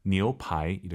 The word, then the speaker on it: 牛排: 니우 아니고 아이유 사이에 오 넣어서 니우, 니우, 니우파이 이렇게 발음하시기 바랍니다.